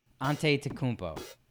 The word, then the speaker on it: Antetokounmpo: Giannis Antetokounmpo. – Okay. This is like the third take. I thought you'd get it by now. – I just wanted you to. – Giannis Antetokounmpo. – Antetokounmpo. – Antetokounmpo.